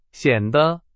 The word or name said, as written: 显得